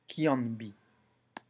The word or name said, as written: qianbi